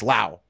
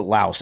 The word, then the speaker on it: Laos: No, you do not say the S. It's Lao. We were saying it wrong. We were like insulting them the whole time. – Dude, the government officials call it Laos.